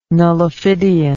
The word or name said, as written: nullifidian